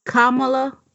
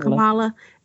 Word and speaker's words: Kamala: and I think her name is pronounced Kamala and not Kamala. Okay, so her name is pronounced Kamala Harris.